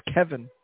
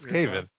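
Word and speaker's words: Cave In: I believe it's pronounced Kevin. – No, it's Cave-In.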